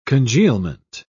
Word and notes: congealment: British English

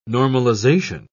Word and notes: normalisation: British English

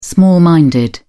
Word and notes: small minded: British English

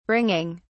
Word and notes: bringing: British English

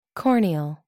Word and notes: corneal: American English

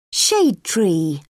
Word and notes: shade tree: British English

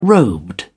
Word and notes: robed: British English

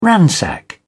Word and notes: ransack: British English

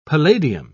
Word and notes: palladium: British English

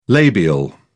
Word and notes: labial: British English

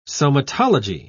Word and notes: somatology: British English

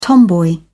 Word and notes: tomboy: British English

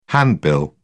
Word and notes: handbill: British English

